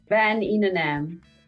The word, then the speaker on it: Van Inenam: Van Inenam. Van Inenam. Yes, so it's, it could be, is it maybe Dutch or? Yeah, it's Dutch.